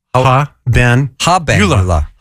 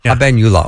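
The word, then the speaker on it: habenula: And one of the things you talked about was the eubenula. Did yes. – I say that right? – Eubenula. Eubenula, yes. – Eubenula. – Ha-ben-ula. – Ha-ben-ula. Okay.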